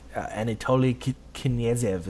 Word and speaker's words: Anatoly Knyazev: Anatoly Knyazev is how you say it, and he says it wrong.